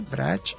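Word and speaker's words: Brač: Una se llama la isla de Brach, que se escribe B larga, R-A-C-H, Brach, que tiene un pequeño pueblo que se llama Bol, B larga o L.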